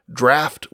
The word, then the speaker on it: draft: The Middle English dracht had that guttural sound, which winds up being spelled with a GH, and in later pronunciation, for whatever reason, draft went the way of laugh rather than caught.